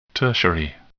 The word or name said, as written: tertiary